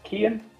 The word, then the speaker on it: Cian: So my name is Cian, which is a ropey Irish name and is not pronounced how it's spelled whatsoever, but Cian like a door key.